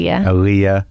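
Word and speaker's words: Elia: Aaliyah Kazan.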